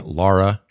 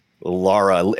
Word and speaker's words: Lara: The, the the matter of fact, there's a reason that her name is Laura L A R A, because originally it was going to be like you spell L A U R A, but the Americans were all pronouncing it wrong.